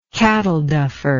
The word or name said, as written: cattle duffer